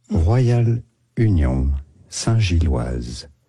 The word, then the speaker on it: Union Saint-Gilloise: What is the proper pronunciation? – In French, it is said Royal Union Saint Gilloise. Royal Union Saint-Gilloise, you could say in English.